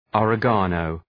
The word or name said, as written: oregano